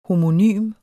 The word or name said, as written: homonym